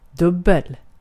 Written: çift

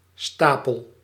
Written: pile

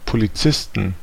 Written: Polizisten

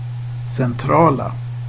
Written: centrala

